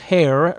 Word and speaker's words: Hare: I remember that because Hare rhymes with baritone.